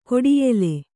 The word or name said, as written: ಗಾಢಾಯ್ಲ